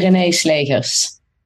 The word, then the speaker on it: Renée Slegers: Renée, give me a crash course on how to pronounce your full name, please. – I say Renée Slegers.